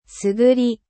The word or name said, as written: groseille